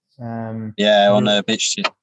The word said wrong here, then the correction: BitChute: BitChute. I keep saying it wrong. I've seen a bit of it. BitChute.